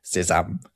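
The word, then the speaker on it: sésame: You can scotch that quite easily by pointing out that sesame in French is pronounced sesame.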